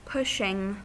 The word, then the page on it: pushing: American English